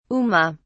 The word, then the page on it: uma: British English